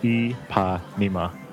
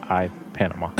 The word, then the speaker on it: Ipanema: It's the Ipanema Tower, pronounced E-pa-nema, not I-Panama.